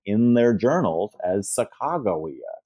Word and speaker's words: Sacagawea: We might know her name as Sacagawea. – I've been saying it wrong the whole time. – Well, so it's interesting. We all learned her name is Sacagawea.